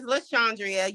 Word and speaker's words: Lashandria: My name is Lashandria. You were close. Look, nobody ever pronounces it right. So the easiest thing is everybody calls me Sean.